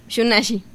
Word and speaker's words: Shunashi: ¿Cómo se pronuncia? – Pues se pronuncia Shunashi, así. Y es zapoteco, significa...